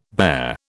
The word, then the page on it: barre: British English